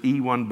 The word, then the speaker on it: Ewan: That's spelled E-W-A-N. You might have heard, I've heard people pronounce it Ewan, but I think it's Ewan is the correct pronunciation.